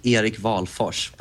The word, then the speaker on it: Wahlfors: The Swedish pronunciation is Erik Wahlfors, but I think turns into like Eric Wahlfors.